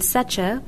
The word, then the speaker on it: Secher: Joost, which rhymes with ghost, and Secher, which rhymes with betcha.